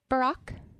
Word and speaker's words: Barak: It's B-A-R-A-K instead of B-A-R-C-K. – Right. So it is pronounced Barak, mm-hmm. that is the correct pronunciation in Hebrew. – It's not Barak. Sometimes when you listen to the Bible, whatever app you're using, sometimes I call him Barak. That's not the proper Hebrew pronunciation.